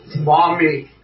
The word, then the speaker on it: Maumee: Maumee.